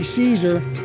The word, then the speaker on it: Czar: Well, that a clip out of Wild Guitar starring Arch Hall Jr., Cash Flagg, William Waters, and Nancy Caesar. Well, it's pronounced, well, it's spelled Czar, but it's Caesar.